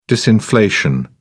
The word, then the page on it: disinflation: British English